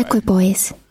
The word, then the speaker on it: equipoise: there wasn't equipoise to the question anymore. – So that word that she is butchering is pronounced this way. – Equipoise.